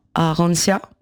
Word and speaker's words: Arantia: Alors, j'ai même pas dit que tu avais ta propre compagnie qui s'appelle Arantia. – C'est ça. – On le prononce comme ça.